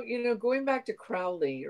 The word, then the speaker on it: Crowley: If you read, there's a uh, a poem that he wrote called "The Beetle," where he rhymes his na- last name with "holy." So I'd say Crowley but I don't really care how people pronounce it. – No, I used I to say Crowley, but I think it's really Crowley.